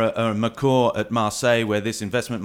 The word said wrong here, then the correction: McCourt: We pronounce the T on McCourt. McCourt, McCourt, sorry, yes.